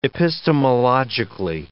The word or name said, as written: epistemologically